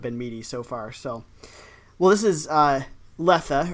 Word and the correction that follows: Lethe: or I, actually no, it's Lethe. Lethe. It's Lethe, mm-hmm. right? – Yeah, it is.